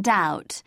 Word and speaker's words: doubt: B must not be heard in doubt. Debt and dumb both leave it out.